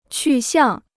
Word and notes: 去向: qù xiàng

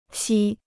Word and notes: 锡: xī

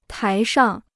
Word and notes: 台上: tái shàng

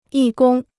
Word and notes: 义工: yì gōng

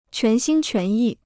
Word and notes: 全心全意: quán xīn quán yì

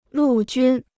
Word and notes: 陆军: lù jūn

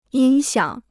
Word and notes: 音响: yīn xiǎng

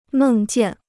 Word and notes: 梦见: mèng jiàn

